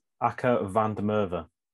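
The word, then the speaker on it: Akker van der Merwe: Akka van der Merwe. I hope I've said that right.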